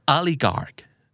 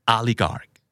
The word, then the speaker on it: oligarch: oligarch oligarch